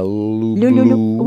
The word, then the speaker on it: люблю: Я люблю. – Will you say it softly, please? Люблю.